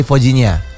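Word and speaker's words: Vozinha: Bacanya Fojinha biasanya ya. – Fojinha.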